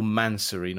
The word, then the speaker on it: Mansory: Oh, Mansory, not Mansonry.